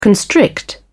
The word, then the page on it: constrict: British English